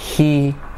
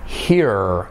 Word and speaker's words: he: He, fijaros que no se dice he. Y fijaros que esa I no es una I corta, sino es una I española. He, no será he, sino he, ¿vale?